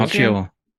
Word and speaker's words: Macchio: Macchio. – Macchio ist 62.